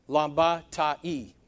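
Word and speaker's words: Lambata-E (συναντιλαμβάνεται): Or I-E is the way it should be. Lambata-E. Okay? You pronounce I as an E in Greek.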